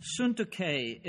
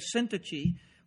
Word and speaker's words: Syntyche: Euodias, or Euodia, and Syntyche is how her name is pronounced. We usually say Syntyche, or something like that, sort of looks that way in our English Bibles.